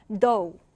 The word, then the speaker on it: dough: GH 발음이 나지 않아요. 그래서 dough, dough 이렇게 발음하는데 밀가루 반죽을 얘기합니다.